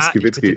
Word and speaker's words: Skibicki: Ja, Skibitzki ist nicht so leicht.